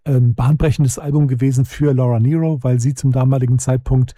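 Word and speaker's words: Nyro: Ich habe die auch jahrelang Nairo genannt oder sowas, aber Nero wird sie tatsächlich ausgesprochen.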